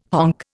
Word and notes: honk: Indian

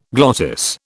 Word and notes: glottis: British English

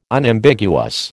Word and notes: Unambiguous: American English

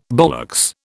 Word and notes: ballocks: British English

